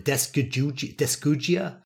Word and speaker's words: dysgeusia: How do you go about pronouncing it? Dysgeusia. Dysgeusia. Dysgeusia.